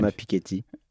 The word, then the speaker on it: Piketty: Thomas Piketty.